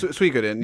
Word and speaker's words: Suikoden: How do you say that? The Suikoden. – Yes, Suikoden. Yeah, Suikoden's right.